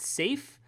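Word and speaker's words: Syaf: This guy, the artist on the issue, Ardian, it's S-Y-A-F, and I have been told that that is pronounced safe by someone who is, he's Indonesian.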